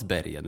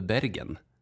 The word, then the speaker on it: Bergen: Som inte ens uttalas Bergen, utan som väl Bergen.